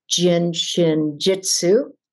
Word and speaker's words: Jin Shin Jyutsu: I'd always been studying, but really grabbed even more onto Jin Shin Jitsu.